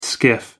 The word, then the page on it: skiff: American English